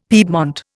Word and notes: piedmont: Indian

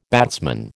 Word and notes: batsman: American English

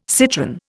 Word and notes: citron: Indian

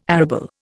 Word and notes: arable: Indian

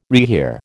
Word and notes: rehear: American English

